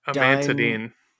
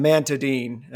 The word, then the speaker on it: amantadine: A amantadine. – Dime, amantadine. Amantadine. Yeah, because somebody asked me, "Isn't that what Jeffrey Dahmer prepared at the table?" Because it's spelled "a man to dine," and uh, which is again kind of funny, actually.